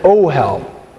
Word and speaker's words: ohel: In Hebrew, a tent is an ohel, O-H-E-L. Everybody say ohel. – Ohel. – Yes. Emphasize the last syllable in that word.